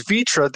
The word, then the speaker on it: fitra: Am I saying that right? V-I-T-R-A? Or is it an F? – It has as F, not V. Vitra. So F-I-T-R-A.